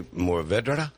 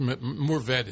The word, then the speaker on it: Mourvèdre: Mourvèdre. – And you say Mourvèdre? – Well, you can sort of drop the last syllable. Just Mourvèdre is fine.